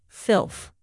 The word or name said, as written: filth